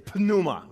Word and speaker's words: pneuma: Like some people say you're not supposed to pronounce the P, and some people say you are supposed to pronounce the P. I think it's more fun to pronounce the P, so I'm going to say Pneuma, right?